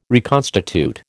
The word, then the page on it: reconstitute: American English